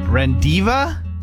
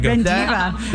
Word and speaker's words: rendezvous: R-E-N-D-E-Z. V-O-U-S. R-E-N-D-E-Z. V-O-U-S. – Rendiva?